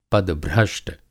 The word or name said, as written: पदभ्रष्ट